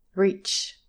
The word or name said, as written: reach